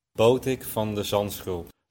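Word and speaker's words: Botic van de Zandschulp: Botik van de Zandschulp.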